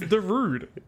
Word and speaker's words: Zarude: It's called Zarude, I think I'm pronouncing that right. – It's Zarude.